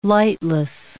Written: lightless

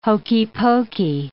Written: hokey pokey

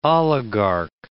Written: oligarch

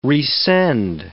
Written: resend

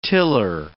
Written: tiller

